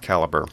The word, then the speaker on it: Calibre: That's Caliber spelled C-A-L-I-B-R-E. It looks like Calibre, which would make sense. I mean, the word Libre implies books, but whatever. I think it's supposed to be pronounced Caliber.